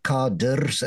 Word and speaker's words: cadre: They are deploying their cadres, as they pronounce it. It should be cadre, but okay.